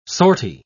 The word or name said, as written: sortie